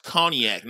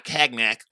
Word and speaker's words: cognac: I have a hard time getting white people understand it's pronounced cognac, not cagnac or cognac.